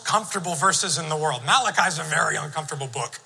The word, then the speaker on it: Malachi: I want you to go with me to the book of Malachi. No, that's not how it's pronounced. Book of Malachi, chapter 2.